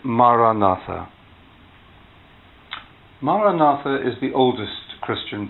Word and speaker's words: Maranatha: If you choose this word, say it as four syllables. Ma-ra-na-tha. Ma-ra-na-tha.